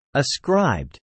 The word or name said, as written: ascribed